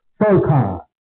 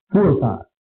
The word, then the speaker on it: Furqan: And if we English speaking people, we might have a tendency to say that the term is pronounced Furqan, Furqan, you know, because of F-U-R in English. But it's not Furqan, it's Furqan, inshallah.